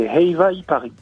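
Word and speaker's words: Heiva i Paris: Est-ce que je l'ai bien prononcé, Eiva y Paris? – Ah oui, vous l'avez bien prononcé, Eiva y Paris.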